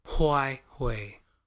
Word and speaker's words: Huawei: Huawei.